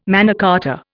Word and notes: Magna Carta: Indian

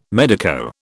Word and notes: medico: British English

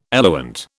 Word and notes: eluent: British English

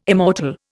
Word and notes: immortal: Indian

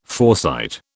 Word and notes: foresight: British English

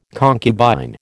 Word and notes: concubine: American English

